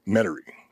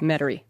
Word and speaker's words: Metairie: Think of the way not to pronounce it, and that's how they say it. – Ah, read it wrong. – Metairie.